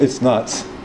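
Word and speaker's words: ITS NTS: So we call this theory, it's the song, not the singer, or ITS, NTS, which is pronounced, it's nuts.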